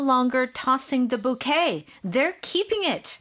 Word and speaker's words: bouquet: Oh, it's very similar. It's just bouquet.